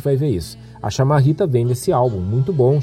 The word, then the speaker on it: chamarrita: E desde a da, da chegada da chamarrita, né, uh, pronunciada desse jeito, né, como eu estou dizendo, chamarrita, como se fosse com CHA e não como CHI,